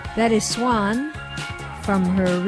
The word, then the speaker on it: Xuan: And I always have to spell it because she doesn't spell it like the regal bird. She spells it X-U-A-N, Swan, Big Blue Ocean.